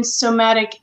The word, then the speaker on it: somatic: She's trained in Gottman method, EFT, couples therapy, and somatic experiencing.